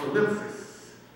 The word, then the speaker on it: thlipsis: It's called ellipsis. T-H-L-I-P-S-I-S.